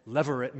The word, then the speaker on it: levirate: Operating behind Naomi's statements in verses 11 through 13 is the provision in the law for something called levirate marriage. That's spelled this way, L-E-V-I-R-A-T-E. L-E-V-I-R-A-T-E, levirate.